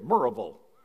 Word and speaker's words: Maryville: Sidebar, the sign says Maryville, but you pronounce it Maryville.